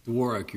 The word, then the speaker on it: Dworak: Dworak, Your Honor. – Just like it's spelled. – Yes. – You may proceed, sir. – Thank you. May it please the court, counsel. My name is Paul Dworak, and I represent respondent Brittany Carls.